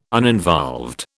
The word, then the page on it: uninvolved: British English